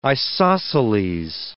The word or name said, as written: isosceles